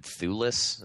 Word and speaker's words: Thewlis: Yeah, you've got David Thewlis, is that how you pronounce it? Yeah,